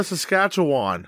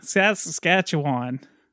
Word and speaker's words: Saskatchewan: Sasquatchian? – You were close. – All the Canadians just fucking cringed. – I don't know how to say it. Saskatchewan? – Regina, Saskatchewan. – Saskatchewan.